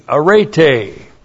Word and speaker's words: arete: A-R-E-T, long E, arete.